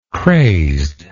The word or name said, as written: crazedness